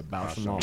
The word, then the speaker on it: Bausch & Lomb: Bausch & Lomb. That's how you say it, right? – Yeah, Bausch & Lomb.